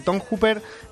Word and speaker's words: Tom Hooper: Eh, dirigida por Tom Hopper, el mismo Tom Hopper, Hooper o Hopper, hay quien lo pronuncia de una manera u otra. Eh, Tom Hooper, ganador del Oscar por El discurso del rey, también director de Los Miserables, de La chica danesa.